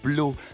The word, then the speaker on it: Blot: Hein, introducing Blow, le one-man band de Monsieur Laurent Blo, Et ça s'écrit B-L-O-T